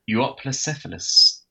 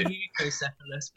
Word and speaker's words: Euoplocephalus: Euoplocephalus. – Okay. – I think I said that right. – I say eucocephalus, but there we go.